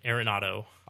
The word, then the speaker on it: Arenado: Spell it for me. Go. – A-R-E-N-A-D-O. Arenado. – Arenado. – Yeah, something like that.